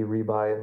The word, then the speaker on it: Ribay: Pretty easy to find online because, to the best of my knowledge, I'm the only Randy Rebuy in the world. Last name is spelled R-I-B-A-Y.